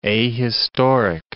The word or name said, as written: ahistoric